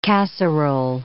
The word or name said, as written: casserole